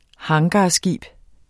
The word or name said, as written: hangarskib